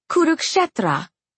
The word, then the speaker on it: Kurukshetra: "Kurukshetra."